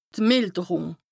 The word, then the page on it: d' Mìlderùng: Bas Rhin; Strasbourg